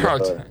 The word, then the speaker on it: Charlton: Charlton. – No, maneja muy bien todos los idiomas. – ¿Cómo es Cebolla? ¿Cómo dijo? – Charlton. Charlton. – Extraordinario.